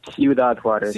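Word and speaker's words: Ciudad Juárez: Ciudad Juarez? – Yeah, that's exactly it. – That's how you say it? Ciudad Juarez? – Ciudad Juarez.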